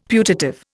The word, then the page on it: putative: Indian